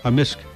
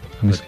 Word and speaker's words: amisk: Amisk. – Amisk. – Amisk. – So the K can be silent, but generally it's...